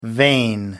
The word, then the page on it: vain: American English